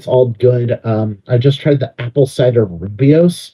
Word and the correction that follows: rooibos: tea. I know I'm probably mispronouncing that, but it's rooibos.